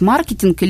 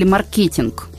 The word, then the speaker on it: маркетинг: Юлия, вы как преподаватель, который уже много лет читает практический курс для маркетологов, вот скажите, пожалуйста, как все-таки правильно произносить маркетинг или маркетинг? – Дело в том, что правильное произношение будет и так, и так.